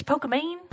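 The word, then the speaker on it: Pokimane: Nominees are Dr. Lupo, Myth, Ninja, Pokemon? Pokemani? – It's Pokemane.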